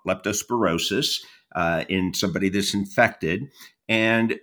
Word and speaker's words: leptospirosis: leptospirosis.